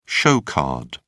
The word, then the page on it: show card: British English